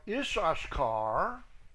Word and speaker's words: Issachar: Verse 28, from the tribe of Issachar, the Y is silent, it's Y-I-S-S-A-S-C-H-A-R. But it's like Israel. The Y is silent. Y-I-S-R-I-E-L.